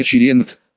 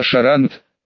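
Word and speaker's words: Charente: Разве только с полным обоснованием вычеркнул из списка Россиньелей дальнобойщицу Аннет и все семейство виноделов из поместья Черент, что на самом деле произносится Шарант, как мне нравоучительно сообщила фрау Шермер, с ударением на последний слог.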